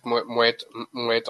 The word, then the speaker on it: Moët: aber ja, das habe ich auch nicht, habe ich eine Zeit lang auch nicht gewusst, aber es wird Moet ausgesprochen.